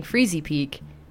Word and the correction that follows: Freezeezy Peak: It's Freezeezy Peak.